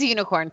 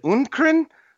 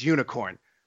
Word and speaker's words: UNIKRN: It is Unicorn. Good job. – Yes, because it's spelled it's spelled UNIKRN, I think. – Mm-hmm. Yes. – And I was like, is it Norwegian? Is it Unkrn? And I was like, no, I think it's Unicorn.